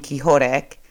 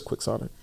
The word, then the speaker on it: quixotic: Your story was dropping Quixotic. That's how I pronounce it, right? – Well, you're thinking of Don Quixote, but the word is Quixotic. – Ah, okay. So Quixotic. I didn't realize you pronounce it like that.